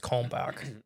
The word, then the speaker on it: Kalmbach: I would not know how to pronounce Kalmbach if I didn't know about the feed that is spelled the exact same way. – Oh, okay. – There's a chicken feed that's Kalmbach.